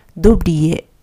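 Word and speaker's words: d'oublier: で、発音は、ドゥまでを一音節で読むので、ドブリエになります。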